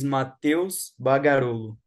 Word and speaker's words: Matheus Bagarollo: Yeah, so my name is Matheus Bagarollo.